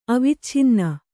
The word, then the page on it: ಅವಚ್ಛನ್ನ: avacchanna